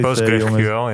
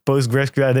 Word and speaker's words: PostgreSQL: Eh, eh, oh ja, hoe, spreek je dat uit? – PostgreSQL, ja. Pro, po, ja, PostgreSQL. Ik dacht dat dat 5 minuten was.